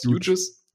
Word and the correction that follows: Jeudy: Ich sag immer huge, aber ich weiß nicht, ob das stimmt. Huge, aber ich sag jetzt mal Jerry Huge. – ja, Jerry Jeudy, nennen wir ihn so,